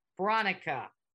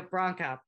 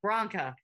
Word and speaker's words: Branca: It is spelled B-R-A-N-C-A. Bronica. We're going to go Bronca. Bronca. – Like Bronco, but.